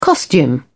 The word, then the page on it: costume: British English